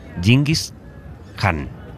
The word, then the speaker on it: Genghis Khan: Hemos quedado en la plaza de Genghis Khan, que aquí pronuncian Genghis Khan.